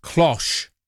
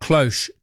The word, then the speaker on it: cloche: No, it's cloche, not cloche, to rhyme with posh.